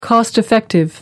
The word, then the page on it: cost effective: American English